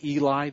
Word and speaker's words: Heli: But you see Joseph's name, and his father was, it's spelled H-E-L-I, but it's pronounced Eli. The H is silent.